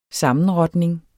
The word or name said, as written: sammenrotning